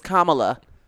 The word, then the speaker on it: Kamala: And that is how you pronounce her name. It is Kamala.